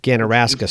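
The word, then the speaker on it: Ganaraska: and then it would be the Ganarasic strain. Is that how you pronounce that? – Ganarasca strain. – Yeah, it's named after the Ganarasca River over by Toronto, Ontario.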